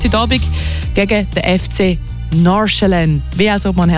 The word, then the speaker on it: Nordsjælland: Also eigentlich ist es gar nicht so eine Sache, aber man würde es so aussprechen. Nachschillen. Das D sagst du eigentlich nicht und das andere ist so Schä. Nachschillen.